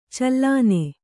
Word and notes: ಚಲ್ಲೆಣ್ಣೆ: kannada

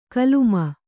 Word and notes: ಕೋಲುಮೀನು: kannada

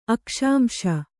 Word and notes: ಅಕ್ಷಾಂಶ: Kannada

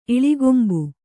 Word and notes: ಇಳಿಗೊಂಬು: kannada

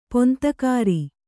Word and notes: ಪಂಥಗಾಱ: panthagāṛa

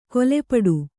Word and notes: ಕೊಲೆಪಡು: kole paḍu

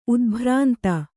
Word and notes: ಉದ್ಭ್ರಾಂತ: Kannada

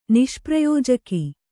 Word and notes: ನಿಷ್ಪ್ರಯೋಜಕ: niṣprayōjaka

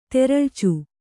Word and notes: ತೆರಳ್ಚು: teraḷcu

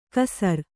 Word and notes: ಕಸರ್: kasar

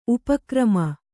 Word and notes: ಉಪಗ್ರಾಮ: upa grāma

